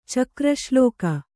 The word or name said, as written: ಚಕ್ರಶ್ಲೋಕ